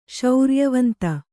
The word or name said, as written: ಶೌರ್ಯವಂತ